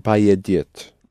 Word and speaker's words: поедет: Он поедет. – Поедет. Accented on е, да? Он поедет.